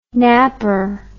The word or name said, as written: knapper